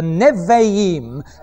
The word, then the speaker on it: Nevi'im: The second group that we come to are the prophets, or as it is in the Hebrew, the Nevi'im. N-E-B for Bertie, H-I-I-M. The Nevi'im. A Beth is pronounced as a V.